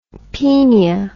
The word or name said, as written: penia